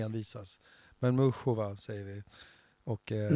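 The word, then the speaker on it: Muchová: Eh, men du, Mukova, så jag säger bara så här, Mukova, eller jag säger ju fortfarande Mukova, nu är vi inne på det här med uttal igen, och Muchova. – Ja, vi ska säga Mushova, eh, Mushova. – Ja, Mushova är det korrekta förstått, sen säger amerikanerna ja.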